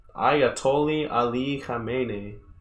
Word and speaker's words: Ayatollah Ali Khamenei: Ayatollah Ali Khamenei.